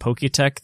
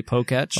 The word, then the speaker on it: Poketch: Is this one of those moments where you realize you've been pronouncing it Poketech this whole time, but it's actually Poketch? – Oh my gosh.